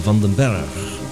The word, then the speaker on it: Vandenberg: De promo die je hoorde was overigens muziek van Vandenberg, oftewel gewoon op zijn Nederlands uitgesproken Vandenberg.